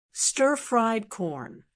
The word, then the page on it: stir fried corn: American English